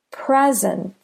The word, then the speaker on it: present: Present. Present. Do you hear how pre is longer than sint? Present. The first syllable is stressed.